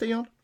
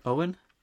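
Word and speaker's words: Eoin: Is that pronounced Ion? Because it's Irish, isn't it? – Owen. – Is it Owen?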